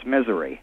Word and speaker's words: Missouri: Yeah, well, it's actually, if you've ever been here, it's misery. – Missouri. But I mean, is that the proper pronunciation from Missourians? – Missouri? – Yeah. – That's it. – That's the way Missourians say it.